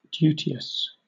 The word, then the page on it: duteous: British English